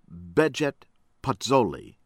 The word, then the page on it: Behgjet Pacolli: American English